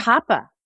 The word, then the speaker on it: COPPA: Wait, that's how you pronounce it? – Providers. – COPPA? – COPPA. COPPA. COPPA. – I've been mispronouncing it my entire career.